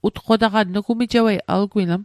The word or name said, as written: utqotaqan